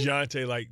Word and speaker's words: Dejounte: DeJounte, like Dijon Mustard. – Is it because he spells it with a J as opposed to a G that throws you off? – Yeah, is that like a silent J, like DeJounte? Like, I think, between that and just the... – Because like Jean, DeJounte.